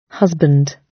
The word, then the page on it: husband: British English